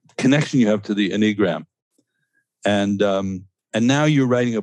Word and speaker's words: Enneagram: Enneagram. So accent on the first syllable.